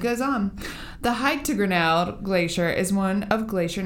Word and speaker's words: Grinnell: Grinnell.